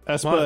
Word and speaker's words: Espa: I hope I'm pronouncing this right, or Eisley, Mos Eisley, and Mos Espa, right? – Espa and Eisley.